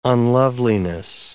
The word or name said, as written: unloveliness